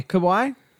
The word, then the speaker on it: Kauai: I'm sure you butchered that, but, you know, people in Hawaii get it. – K-A-U-A-I. – Yeah, I don't know. – Kauai? – It could be Kauai. – Kauai? We're going with Kauai.